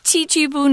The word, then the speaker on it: Chichibu: The first one we talked about, of course, was the Chuchubu is that how you say it? – My uh, Japanese. Chichibu. Is... – Chichibu. Uh, that's how I learned it, I guess.